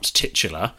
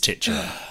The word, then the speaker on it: titular: Last week, when Ben pulled me up on my pronunciation of titular, I very adamantly said, it's not titular. And again, having consulted my phonetic and BBC Oxford English pronunciation guides, both of them, I'm wrong. It is titular. I think it's just that I try to avoid tits and have spent 30 years trying to do that. I've got rid of any tit in my pronunciation.